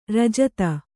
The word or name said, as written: ರಾಜಿತ